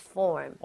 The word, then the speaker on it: form: 발음할 때 여러분 제가 항상 트레이닝 시켜드리죠. 그냥 form. form은 더안 됩니다. f니까 입술 붙으면 안 돼요. 거기에서 r 먼저 혀로 당겨주고 닫아도 늦지 않다고 했습니다. 그래서 form 하지 마시고 form의 느낌으로 가볼게요.